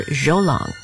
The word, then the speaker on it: Zhou: We're always glad to share music by Chinese-American composer Zhou Long. And by the way, his first name is not J-O-E, it's spelled Z-H-O-U.